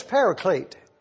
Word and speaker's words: Parakletos: It's the Greek word P-A-R-A-K-L-E-T-O-S. Parakletos. We would say it like this, paraclete.